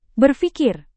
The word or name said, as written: berfikir